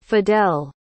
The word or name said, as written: fidel